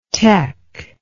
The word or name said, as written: tec